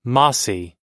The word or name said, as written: mossie